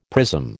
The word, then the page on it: prism: American English